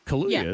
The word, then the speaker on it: Kaluuya: Now, I'm probably going to butcher his last name, but Daniel Kaluuya. You know him, the actor? He was in the movie Get Out. I think it's other things. – Kaluuya, isn't it? – Yeah, I think you said it right.